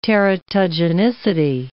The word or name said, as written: teratogenicity